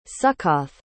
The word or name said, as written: sukkoth